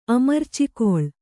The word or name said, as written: ಅಮರ್ಚಿಕೊಳ್